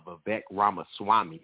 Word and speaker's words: Vivek Ramaswamy: In regards to the gentleman you were speaking of, Don Vivek Ramaswamy is how you pronounce his name.